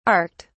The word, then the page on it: arced: British English